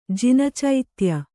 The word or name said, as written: ಜಿನಚೈತ್ಯ